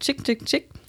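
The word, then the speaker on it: Chk Chk Chk: Jetzt kommen nämlich drei Ausrufezeichen, die wohl irgendwie jeden Reporter, Musikliebhaber irgendwann mal zum Verzweifeln gebracht haben. Mittlerweile haben sie es allen einfach, einfacher gemacht und haben die Ausspracheempfehlung in den Bandnamen eingefügt. Es ist natürlich die Rede von Chick Chick Chick aus New York.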